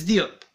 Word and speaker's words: Diop: Bates Diop. – Oh, Diop.